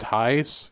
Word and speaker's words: Thijs: I believe this name is pronounced Thijs. I think it's a Dutch name. Spelled T-H-I-J-S.